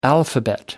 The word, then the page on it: alphabet: American English